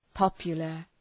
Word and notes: popular: ‘pɒpjələr

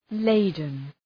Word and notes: laden: ‘leıdən

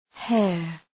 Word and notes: hair: heər

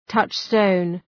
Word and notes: touchstone: ‘tʌtʃstəʋn

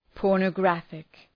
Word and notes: pornographic: ,pɔ:rnə’græfık

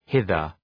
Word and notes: hither: ‘hıðər

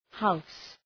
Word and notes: house: haʋs

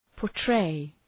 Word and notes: portray: pɔ:r’treı

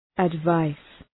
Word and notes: advice: æd’vaıs

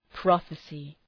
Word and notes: prophecy: ‘prɒfısı

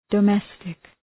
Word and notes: domestic: də’mestık

